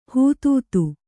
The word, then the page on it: ಹೂತೂತು: hūtūku